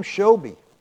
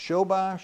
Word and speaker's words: Shobi: This guy named Shobi, or you can pronounce it Shobai, Shobi.